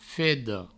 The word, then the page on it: feda: fedo; Provençal